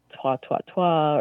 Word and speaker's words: toi toi toi: They'll say like, twa, twa, twa, or something before he goes up there. And thought it was kind of funny. – And any idea how to spell that? – She told me it was like T-O-I, T-O-I, T-O-I. – Yeah, yeah, that's how it usually appears as three words, toy, toy, toy.